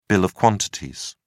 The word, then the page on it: bill of quantities: British English